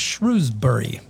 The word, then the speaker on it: Shrewsbury: It's spelled with an E. – I know. – Like a shrew, like the animal. – I know, but that's not how it's pronounced. – Shrewsbury. – Yes. – Okay. – Sort of rhymes with rosemary, kind of.